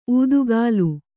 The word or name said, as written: ಉತ್ಕಲಿ